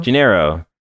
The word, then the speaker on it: Janeiro: Janeiro. I realize that I say that wrong.